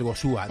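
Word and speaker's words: goxua: Dice que él allí destaca, espero pronunciarlo bien, el Gosuá. Dice que es un dulce que está... El Gosuá. – Sí, sí, el Gosuá, delicioso.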